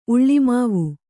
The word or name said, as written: ಉಳ್ಳಿಮಾವು